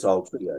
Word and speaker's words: Altria: I want to go to a third case, which is, correct me if I pronounce this, Altria Corporation v. Good. – I believe it's Altria, yes.